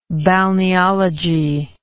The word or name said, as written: balneologist